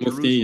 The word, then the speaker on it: Mufti: Of Mufti.